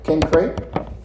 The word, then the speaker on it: Cenchreae: Paul says this, I commend to you our sister Phoebe, a servant of the church at Cenchreae, or some people pronounce it Cenchreae,